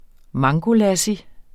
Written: mangolassi

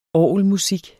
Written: orgelmusik

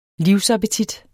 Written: livsappetit

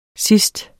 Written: sidst

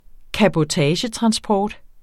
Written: cabotagetransport

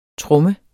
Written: tromme

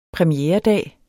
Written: premieredag

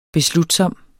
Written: beslutsom

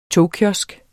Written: togkiosk

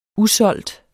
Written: usolgte

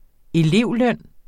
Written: elevløn

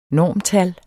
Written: normtal

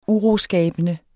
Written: uroskabende

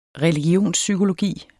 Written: religionspsykologi

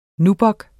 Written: nubuck

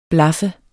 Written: blaffer

